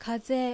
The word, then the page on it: Kaze: Japanese